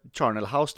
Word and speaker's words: Charnel House: Ja, Char eller Charnel House tror jag det uttalas.